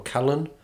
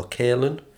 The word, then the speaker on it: Kalen: The first was Leandro Kalen, or Kalen, it's spelled K-A-L-E-N, A Tale of Southern Groove.